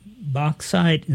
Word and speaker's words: bauxite: We mentioned some of the environmental impacts of mining the bauxite.